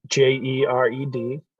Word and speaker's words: Jered: Jered Scott. Yeah. Jered.